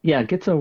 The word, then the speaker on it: Gitzo: Is it Gitzo? Is that how you say it? Gitzo? – Gitzo works.